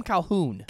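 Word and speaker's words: Colqhoun: A Calquin? – They call him Calhoun. – Calhoun. – But it's spelled with a Q.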